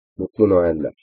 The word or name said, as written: mutlu noeller